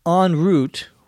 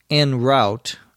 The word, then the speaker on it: en route: En route, which is sometimes pronounced en route, although the preferred pronunciation is en route or en route, is a French expression.